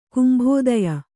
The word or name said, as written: ಕುಂಭೋದಯ